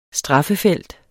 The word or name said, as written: straffefelt